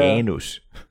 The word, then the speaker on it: Janus: You have to work on your flat A's. – Yeah. – Janus. – Janus.